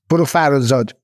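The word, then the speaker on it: Farrokhzad: How am I pronouncing her right? Farag Farazad? – Farazad.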